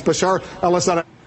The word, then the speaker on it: Bashar al-Assad: I know you've mispronounced his name a few times, but it's Bashar al-Assad.